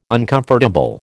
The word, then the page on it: uncomfortable: American English